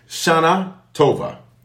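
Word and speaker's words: Shanah Tovah: S-H-A-N-A-H space. Tova, really, is how you say it. T-O-V-A-H. I'm just making an American for us. American. All right. Okay. Shana Tova.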